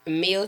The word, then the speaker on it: Miell: M-I-E-L-L, the number 2, R-E-A-L. – Because somebody would have put M-E-A-L. Thank you a whole meal. – Yeah. I am a meal, but it's spelled like meal.